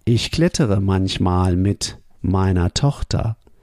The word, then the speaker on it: meiner: Notice the pronunciation. The er at the end sounds like a. So it sounds like mit meiner Tochter.